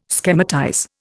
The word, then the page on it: schematize: Indian